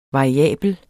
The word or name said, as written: variabel